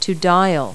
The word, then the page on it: dial: verb